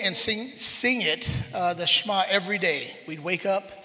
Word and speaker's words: Shema: It's S-H-E-M-A, pronounced Shema. You say it fast.